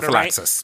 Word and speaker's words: prophylaxis: Another bill signed by Polis allows pharmacists to prescribe and dispense pre-exposure prophylactis. I'm going to say I said that wrong. Said it sort prophylaxis.